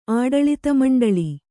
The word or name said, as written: ಆಡಳಿತಮಂಡಳಿ